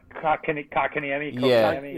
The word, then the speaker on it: Kotkaniemi: Yeah, Kotkaniemi.